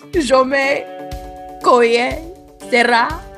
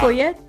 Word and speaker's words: Jaume Collet-Serra: It's Jaume. Is that right? – It's close. Keep going. – Jaume Coyet Serra. – Yeah. – Coyet Serra. – Coyet. Yeah.